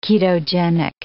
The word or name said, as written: ketogenic